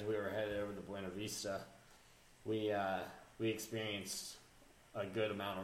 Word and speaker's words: Buena Vista: It's pronounced Buena Vista. – I know. – Shut up, Ben. – They don't pronounce it correctly in their own town.